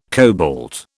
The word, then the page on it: cobalt: British English